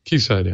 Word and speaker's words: Quayside: Quayside, yeah. – It's spelled Quayside, but as I learned quickly... – Yeah, it's key like the British key.